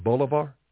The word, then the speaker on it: Bolivar: I just read the other day, Venezuela, I believe they pronounce it Bolivar, B-O-L-I-V-A-R, million dollar bill, if I recall, $500,000 bill and a $100,000 bill.